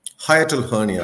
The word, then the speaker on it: hiatal hernia: What is it pronounced? A hiatal hernia? – Hiatal hernia. – Hiatal hernia. – H-I-A-T-A-L. – Yes.